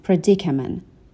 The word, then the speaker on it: predicament: The best way I know to overcome this predicament. Overcome به معنای غلبه کردن هستش و predicament هم قبلا داشتیم به معنای مخمصه و گرفتاری و مشکل. به تلفظش دقت کنین. Predicament.